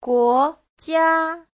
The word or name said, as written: guo2jia1